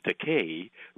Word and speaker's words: Takei: And it's so silly and it's so stupid that I said, well, since my surname Takei rhymes with gay, then they can use Takei in its stead.